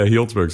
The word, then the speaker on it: Healdsburg: Healdsburg, is that how you pronounce it? – Yeah, Healdsburg.